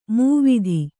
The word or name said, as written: ಮಾವ್ತ